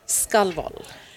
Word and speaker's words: Skalvoll: Skalvol. – Right. So Maddie and I tried it earlier. You'll notice I didn't pronounce it. There was a reason why, because I'm like, I said skull.